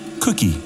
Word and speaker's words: Koki: Janet and Brad discover that their rescuer's name is spelt K-O-K-I, but pronounced Cookie, which he explains to them with a wide smile.